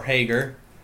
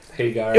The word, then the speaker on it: Hager: Because obviously we have the big heel turn at the end with the debut of Jake Hagar, or Hager, depending on how you want to pronounce it. – Hagar.